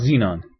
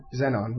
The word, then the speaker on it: Zenon: Is it Zenon or is it pronounced Xenon? Do they ever say it in the game? – Yeah, I think it's Zenon. – Zenon? – Zenon, yeah. – Zenon? Not Xenon? Okay, you know what the code name for the Xbox 360 processor was? Xenon. X-E-N-O-N.